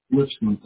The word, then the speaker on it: Lipscomb: Now, Cliff, your first name is spelled C-L-I-F-F? – Yes, ma'am. – Okay, and what's your last name? – Lipscomb, L-I-P-S-C-O-M-B. – Can you say that one more time? – L-I-P-S-C-O-M, O as in orange, M as in Michael, B as in boy.